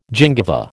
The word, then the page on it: gingiva: American English